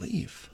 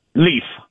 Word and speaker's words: Leif: Is it Leaf or Leif? – Leaf. – Nice. – How do you spell that? – What's your last name? I got questions. – L-E-I-F. It's Norwegian.